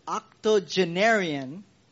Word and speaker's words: octogenarian: Through a Octagonarian, I think that's how you say it. Octogenarian.